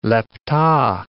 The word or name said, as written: lepta